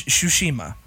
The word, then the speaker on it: Tsushima: It's Tsushima. The T is not pronounced.